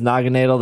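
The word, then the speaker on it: Naganadel: In the happy anniversary graphic, there is a Hisuian Goodra, there is Sprigatito, Quaxleaf, Waikoko, there's an Alcremie, there's Diancie, Naginadel, which no one knows how to say that Pokemon's name right. I believe it is Naginadel. That's how they say it in the anime, at least.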